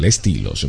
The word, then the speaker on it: Estilos: Estilos. Lo pronuncié en inglés, pero en español, Estilos, ¿ok?